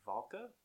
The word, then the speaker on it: Valka: She plays Valka. Is that how you say it? – Yep.